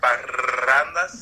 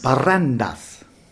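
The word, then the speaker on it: parrandas: Can you roll your R's? Barrandas? – Barrandas. – Yeah, there you go.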